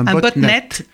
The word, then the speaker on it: botnet: botnet. – oui. Alors deuxième syllabe du mot robot, bot. Première syllabe du mot network.